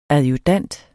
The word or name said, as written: adjudant